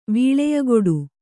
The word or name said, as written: ವೀಳೆಯಕೊಡು